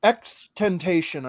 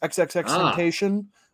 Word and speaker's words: XXXTentacion: Now, XXXTentacion, I believe is how it's pronounced. XXXTentacion.